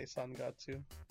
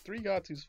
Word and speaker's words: Sangatsu: I guess some people like say Sangatsu. – Sangatsu. – Because it's the Japanese pronunciation, but Thrigatsu's fine. Don't make fun of me. I know some purists being like, why aren't you saying the full Japanese name Sangatsu? – Well, I'm not Japanese, so.